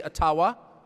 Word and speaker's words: Ottawa: It cannot be taken from you, no matter who is in Ottawa, or as we say, Ottawa, which, by the way, is the correct pronunciation, according to my friends in the Ojibwa community.